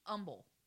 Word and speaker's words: Humble: Texas, Uh uh You said that wrong. Humble. – Humble, Humble. Humble, Right, like it's not herb, it's herb. – Right, oh, the H doesn't. The H is silent?